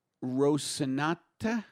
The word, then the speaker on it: Rocinante: And he traveled in a truck named for Don Quixote's horse, Rocinante? – Not close. – How do you say it? – I don't know. – Rocinante. Rocinante. Thank you.